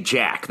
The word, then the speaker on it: Jacques: Though I do think they say Jack. They say Jack. – Jack, sure.